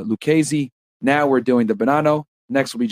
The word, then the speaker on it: Bonanno: The Bonanno crime family, pronounced Bonanno,